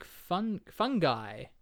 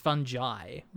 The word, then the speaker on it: fungi: they pronounce it in the film, and this is, I guess, the American pronunciation, as Fungi, which I don't like that at all. I don't think that's correct. – That's odd. – Yeah, it's fungi, fungi.